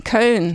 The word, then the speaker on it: Köln: Köln, Köln. In German.